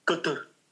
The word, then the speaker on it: Qatar: And so it's Qatar, right? – Kutur.